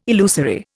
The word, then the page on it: illusory: Indian